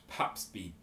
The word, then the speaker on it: Papsby: My name is Josh at Papsby, P-A-P-S-B-Y.